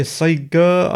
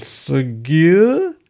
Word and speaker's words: Sgeyr: When I say Sega, I don't mean Sega. I mean Segur. It's spelled S-G-E-Y-R.